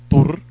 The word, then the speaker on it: Thor: Y Thor, si lo pronuncian en nórdico, Thor, les encantó.